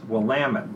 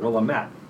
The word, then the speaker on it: Willamette: It's pronounced Willamette, not Willamette.